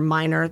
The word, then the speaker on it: Miner: If you don't follow me on Instagram yet and you are listening, please go to Kathleen underscore Miner. That is spelled C-A-T-H-L-E-N-E underscore M-I-N-E-R.